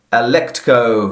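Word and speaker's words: Alecto: To achieve this end, she enlists the fury Alectco. – Alectco. Aletto. Yeah, you said it right.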